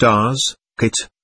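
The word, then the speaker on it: Asket: This new voice explained that she was a woman called Askit, pronounced Askit, and that she would now be his new companion.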